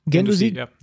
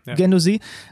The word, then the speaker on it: Guendouzi: Also ich habe auf den geachtet, wird er überhaupt so ausgesprochen? – Guendouzi, Guendouzi.